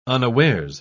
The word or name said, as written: unawares